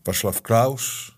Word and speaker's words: Václav Klaus: Václav Klaus, zeg ik het goed? Ja, goed hè? Hoe oud ik had het zo waar goed.